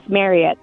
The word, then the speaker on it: Marriott: So the actual family last name, instead of pronouncing it Chariot, it's Marriott.